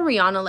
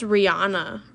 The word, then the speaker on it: Rihanna: her name is pronounced Rihanna, right? Rihanna. I think it's Rihanna.